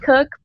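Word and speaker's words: Koch: It's Jade, J-A-D-E, and then my last name. It's pronounced Cook, but it's spelled a little funky. It's K. O-C-H, but on the social media, the O is a zero. So it's J-A-D-E-K-0-C-H.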